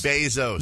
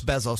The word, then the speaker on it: Bezos: Bezos. – No, it's Bezos. – Bezos. – Bezos. – Bezos? – Yes. – Okay. – It's how you pronounce it in 17 different countries.